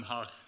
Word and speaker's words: Den Haag: Den Haag. – Den Haag.